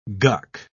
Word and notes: guck: British English

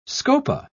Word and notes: scopa: British English

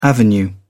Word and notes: avenue: British English

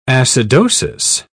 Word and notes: acidosis: British English

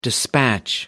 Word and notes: dispatch: American English

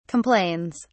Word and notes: complains: British English

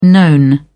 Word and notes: known: British English

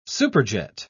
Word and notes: superjet: British English

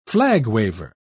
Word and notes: flag-waver: British English

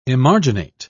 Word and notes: emarginate: British English

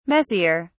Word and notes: messier: British English

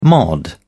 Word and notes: mod: British English

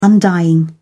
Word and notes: undying: British English

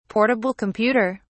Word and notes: portable computer: British English

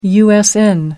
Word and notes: usn: American English

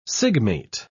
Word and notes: sigmate: British English

